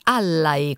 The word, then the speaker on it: Allai: Cara Sabrina, si dice Allai con l'accento sulla prima A.